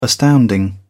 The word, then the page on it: astounding: British English